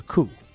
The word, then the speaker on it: coup: Nobody pronounces the P in coup? – No, they do not. It's a coup.